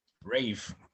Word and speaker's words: Ralph: Rave. Wat een rave? – Ja, het is als Ralph geschreven, maar je moet het als Rave uitspreken.